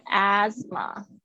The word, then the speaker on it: asthma: 那这边呢，我要跟告诉大家，就是英式发音是发 s，美式发音是发 z，所以看你比较喜欢哪一种声音，有可能是 asthma 或者是 asthma，就是很轻微的差别。看一下 s 跟 z 的差别，asthma 或者是 asthma，就是这个 s 的声音。 th 这边没有发音，气喘。